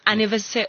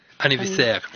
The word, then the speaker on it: anniversaire: Anniversaire. – So it's French. Yeah. How do you pronounce it? – Anniversaire.